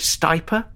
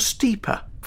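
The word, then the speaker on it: Stipa: Do you say stiper or steeper for the feathery grass and the golden oat? Both, it seems, are acceptable.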